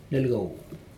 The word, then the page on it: nelcau: Aneityum